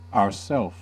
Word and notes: ourself: American English

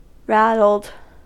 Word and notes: rattled: American English

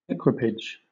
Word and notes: equipage: British English

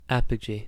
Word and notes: apogee: American English

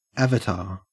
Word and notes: avatar: Australian English

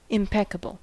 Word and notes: impeccable: American English

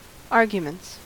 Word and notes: arguments: American English

